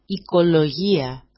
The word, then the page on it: ikolojia: ikoloji-a